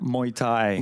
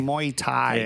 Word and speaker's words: Muay Thai: Muay Thai. – Muay Thai.